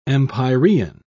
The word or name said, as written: empyrean